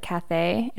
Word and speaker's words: Cathay: Yeah, C-A-T-H-A-Y. – Oh, how do you pronounce it again? – Cathay. – Cathay, okay.